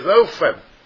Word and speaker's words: Ophan: The word pronounced in the Hebrew there is Ophan. O-P-H-A-N. Ophan. Ophan. The P-H is pronounced as an F, I guess.